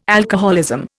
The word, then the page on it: alcoholism: Indian